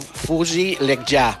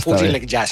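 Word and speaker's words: Fouzi Lekjaa: Creiem que és una cosa així com Fouzi Lekja, tot i que si ho demanes a alguna persona del Marroc com es pronuncia, diuen Fouzi i un cognom sense cap vocal que som incapaç de reproduir en aquests moments. Però vaja, Fouzi Lekja seria.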